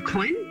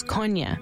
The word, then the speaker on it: Kony: So one interesting thing about Joseph Kony is that his name is Joseph Coyne, if you pronounce it in the local language.